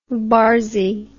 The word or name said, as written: barsy